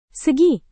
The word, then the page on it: segi: indonesian